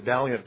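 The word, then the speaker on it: Vaillant: He pronounces it Valiant, but it's V-A-I-L-L-A-N-T. And it's called The Natural History of Alcoholism.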